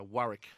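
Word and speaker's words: Wurruk: Uh, Warwick.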